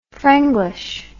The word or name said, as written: frenglish